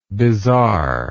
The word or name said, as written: bizarreness